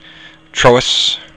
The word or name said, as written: triubhas